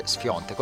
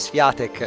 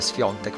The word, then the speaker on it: Świątek: Świątek, che si legge Świątek, ora lo so, non lo sapevo, leggevo sempre Świątek e ora è Świątek, lo so.